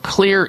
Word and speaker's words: Xlear: He is the CEO of Clear Inc. That's spelled X-L-E-A-R, Clear Inc. The X in Clear stands for xylitol, and we're going to be talking about that shortly here.